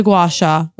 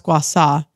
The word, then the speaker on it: gua sha: The gua sha, which I guess is actually pronounced gua sa.